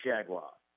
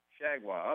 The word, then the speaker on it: Chargois: So in recent days, the Twins have sent out Jake Reed, Mason Melitakis, JT Chargois. Am I pronouncing that right, by the way? – JT Chargois. – Chargois.